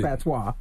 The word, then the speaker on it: patois: Patois. – Patois. – The S is silent.